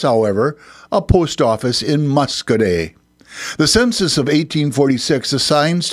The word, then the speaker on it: Muscoda: This time, Muscoday is spelled as it is, M-U-S-C-O-D-A, but there is a Y at the end, Muscoday.